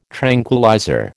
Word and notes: tranquillizer: American English

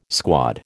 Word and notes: squad: American English

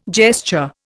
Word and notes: gesture: Indian

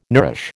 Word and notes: nourish: American English

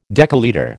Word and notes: decalitre: American English

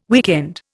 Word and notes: weekend: Indian

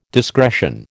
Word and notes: discretion: American English

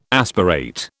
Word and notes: aspirate: British English

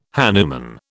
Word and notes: hanuman: British English

